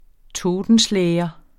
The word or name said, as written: totenschlæger